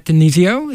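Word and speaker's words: DiNizio: The next radio obituary is for Pat D'Annunzio. Is that how you pronounce his name? – Yep.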